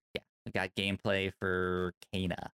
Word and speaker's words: Kena: Time for Kena. Oh, right, that happened. – Time for pronounced Kena. Pronounced Kena now. – Excuse me. – Kena. Not Kena. Kena.